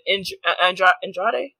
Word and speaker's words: Andrade: And her issues have been Andrade? Is that how you say it? Mm-hmm.